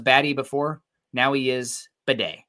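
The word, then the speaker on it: Biadasz: He says, is Tyler's name pronounced Beatty or Bidet? He said it's Bidet. It's obnoxious. when he's hurt on the sidelines, it's Bidet. He went by Batty for a bit,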